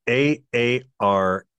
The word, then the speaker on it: Aarhus: My number 9, and I'm going to butcher this, is Aarhus. It's Aarhus.